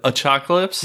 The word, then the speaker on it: Achocalypse: I got a hold of their Achocalypse Chocolate Porter. – Achocalypse? Like apocalypse, but Achocalypse? – Yep.